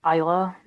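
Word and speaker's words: Islay: Is that how you pronounce it? – Well, some folk when they're talking about the whiskey they'll call it Isla, but I've got a pal and it's spelled like that, and she goes by Eile.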